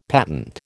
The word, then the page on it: patent: American English